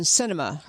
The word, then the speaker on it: Sinema: Sinema. Her last name is, it sounds like the word, like a movie, cinema, but it's S-I-N-E-M-A.